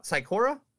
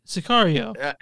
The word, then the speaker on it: Sicario: Psychora? – Okay. – With Del Toro. – Oh, you know what I'm talking about? – Sicario.